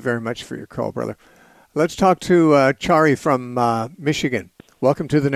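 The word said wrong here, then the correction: Cheri: Sherry. Okay. It's spelled wrong here. – No, it's C-H-E-R-I.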